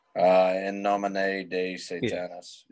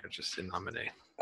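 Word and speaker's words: In nomine Satanis: Venom is doing a 40th anniversary deluxe vinyl box set of, I can never pronounce this, In Namin Satanis? Am I saying that right? – In nomine de Satanis. Yeah. – There's no day in there, just in nomine.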